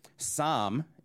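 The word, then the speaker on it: Sam (สาม): Sam is how you say that in Thai, for those of you that probably don't care.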